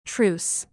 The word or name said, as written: truce